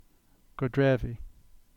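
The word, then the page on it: Godrevy: Cornish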